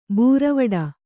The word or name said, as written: ಬೀರವಟ್ಟ